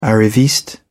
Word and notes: arriviste: British English

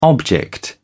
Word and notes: object: British English

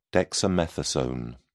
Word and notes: dexamethasone: British English

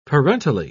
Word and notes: parentally: British English

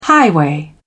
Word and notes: highway: British English

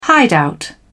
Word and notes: hideout: British English

